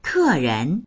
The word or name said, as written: ke4ren2